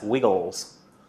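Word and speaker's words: WiggleZ: Sorry, I'm told we're not supposed to pronounce it Wiggle Z. It's supposed to be pronounced Wiggles, but it's spelt with a Z at the end.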